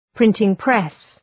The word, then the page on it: printing press: ‘prıntıŋ,pres